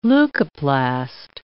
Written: leucoplast

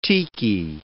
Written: tiki

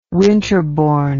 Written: winterbourne

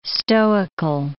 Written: stoical

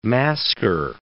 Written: masquer

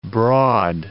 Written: broad

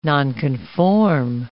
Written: nonconform

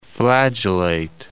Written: flagellate

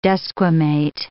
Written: desquamate

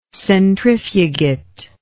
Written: centrifugate

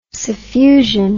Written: suffusion

